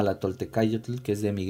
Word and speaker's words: Toltecayotl: Em, se escribe así como suena Tolte-K con C de casa, yo con Y y tl, TL al final, Toltecayotl